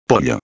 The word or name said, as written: pollo